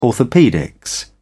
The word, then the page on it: orthopaedics: British English